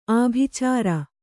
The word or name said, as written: ಆಭಿಚಾರ